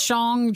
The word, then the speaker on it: Shang-Chi: We're still a couple weeks away from the release of Marvel's Shang-Chi. Am I saying that right? – It is Shang-Chi.